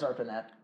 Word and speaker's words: ARPANET: Did I say that right? – Yes, ARPANET.